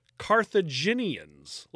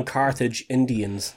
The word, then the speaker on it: Carthaginians: Now, it's commonly believed that crucifixion began in the 6th century BCE with the Assyrians, Persians, Macedonians, and Carthaginians. Look at that, I said it right this time. – Oh, you didn't call them Carthage Indians this time.